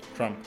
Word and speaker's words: Trump: Trump. – Trump. – ¿Lo dije bien? Bien, Es como que tenés que hacer como una che.